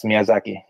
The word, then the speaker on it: Miyazaki: Yes, Miyazaki.